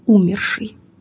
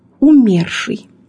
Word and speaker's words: умерший: Во всех этих примерах представлен ошибочный вариант с ударением на первом слоге «умерший». Необходимо запомнить, ударение в слове «умерший» падает на второй слог «умерший» и не смещается при изменении «умершего», «умершему», «об умершем».